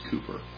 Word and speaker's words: Cowper: Cooper Cowper, it's spelled with a W. It's actually pronounced Cooper.